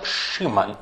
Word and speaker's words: Шимон: Из Писания мы знаем, что в начале своего пути с Господом его имя было Шимон, или в греческом произносится как Симон, Семен.